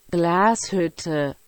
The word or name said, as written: Glashutte